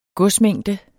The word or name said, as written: godsmængde